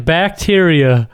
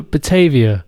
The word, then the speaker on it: Batavia: How do you say it? Bacteria? Bacteria? – Sure, we'll go with that, just for you. – Batavia. – Yeah, there you go. – Batavia. Sounds like potato.